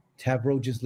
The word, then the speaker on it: Tavroges: Or if you want to email me, my address is info, I-N-F-O, at Tavroges Legal, exactly how my name is spelled, T-A-V-R-O-G-E-S-L-E-G-A-L, so one word, .com.